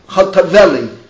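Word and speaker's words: Hotovely: Sippy Chovatova, how do you pronounce the last name? – Chotaveli. – Sippy Chotaveli.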